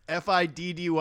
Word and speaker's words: 50 Cent: Why do you pronounce the F, the second F in 50 Cent? – Well, that's his name. It's not. They didn't spell it out without the second F. It's 50. – It's FIDDY, my friend.